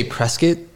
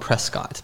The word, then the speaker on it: Prescott: Well, we say Prescott. That's like the local pronunciation. It's spelled Prescott. But if you say Prescott, we know you're not from Prescott.